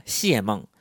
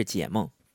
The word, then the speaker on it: 解梦: 那大家有注意到他刚才说谢梦，这个因为这个解和谢是多音字，所以可能读屏这边会读谢梦，但大家知道就行了啊，其实是解梦。